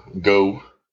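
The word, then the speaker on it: Geaux: Go.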